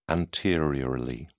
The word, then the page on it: anteriorly: British English